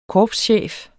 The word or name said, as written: korpschef